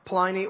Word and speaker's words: Pliny: Now, the interesting thing about Trajan's persecution is there's a very famous correspondence between a governor named Pliny, P-L-I-N-Y, Pliny or Pliny, as some people pronounce it, and Trajan.